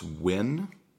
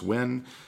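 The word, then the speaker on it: Nguyen: So today's question comes from my friend Sunny, and I believe it's pronounced Wynn. Okay, it's N-G-U-Y-E-N. I believe that's pronounced Wynn. Forgive me if it is not.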